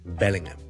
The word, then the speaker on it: Bellingham: Today, there is a listener to watch football asked 10号球衣的球员 Jude Bellingham 他的英文名应该怎么读呢中文媒体好像喜欢译做贝宁咸其实很多人很多时候他的HM都是不读的例如 David Beckham Beckham 我们很多时候他的HM是不读的另外有一个地方名叫做伯明翰虽然我们译做伯明翰有个HM 但是 Birmingham B-I-R-M-I-N-G-H-A-M 那个HM的H都是不读的就读Birmingham 就是Bellingham B-E-L-L-I-N-G-H-A-M 很多时候如果你是英国譬如你报英国新闻你会听到他们的HM都是不读的所以这个人名是Jude Bellingham Bellingham 銀河包銀當是類似 okay? Bellingham, Bellingham, Jude Bellingham Jude Bellingham 這個HM是不需要讀出來的